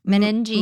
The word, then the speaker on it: meninges: Meninges. – Meninges? It sounds like Menendez.